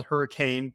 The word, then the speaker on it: Hurricane: It's spelled Hurricane, but it's Hurricane.